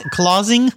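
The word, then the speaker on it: Klasing: The publisher is a German publisher, Delius. Well, I'm going to say this correctly. – Clausing? – Right.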